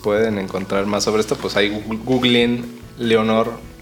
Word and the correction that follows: Lenore: ¿Eleonor o Leonor? – Le, se escribe Lenor. Lenore. Lenore.